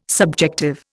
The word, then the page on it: subjective: Indian